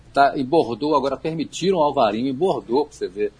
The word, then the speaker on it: Alvarinho: Em Portugal é Alvarinho com V e NH, e na Espanha é Albarinho com B de bola e o N com tio em cima, né, como se escreve em espanhol.